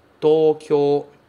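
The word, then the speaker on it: Tóquio (東京): Mas em japonês, em Nihongo, a pronúncia é Tóquio. Tóquio. Tem prolongamento nos dois O's, ok? Tóquio. É prolongado. Tóquio. São quatro, digamos assim, quatro sílabas, certo?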